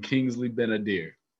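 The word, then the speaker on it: Kingsley Ben-Adir: Is it Eli Gore and Kingsley Benadir.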